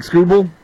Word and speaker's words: Skubal: Okay, and Skubal, is that how you pronounce his name? Yeah, Tarik Skubal.